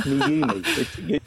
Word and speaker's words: Meyume: Now, when I read it, it says me, you, me. M-E-Y-U-M-E. Me, you, me. Is it me, you, me? – That's right. It's me, you, me. You pronounce it right.